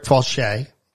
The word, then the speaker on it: Faucher: The guy's name was Rick Fauché. It's spelled F-A-U-C-H-E-R. – It's the Louisiana spelling, kids. Like Thibodeau.